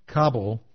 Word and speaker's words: Kabul: It's pronounced to rhyme with rubble, Kabul.